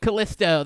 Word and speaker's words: Callisto: Callisto, that's right.